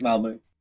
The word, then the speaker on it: Malmö: Headquarters Malmö. – I was just looking on the map. It's near a very big bridge that goes to Copenhagen. – Exactly. I actually live in Copenhagen and work in Malmö.